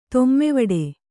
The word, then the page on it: ತೊಮ್ಮೆವಡೆ: tommevaḍe